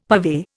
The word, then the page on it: purvey: Indian